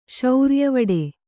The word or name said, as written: ಶೌರ್ಯವಡೆ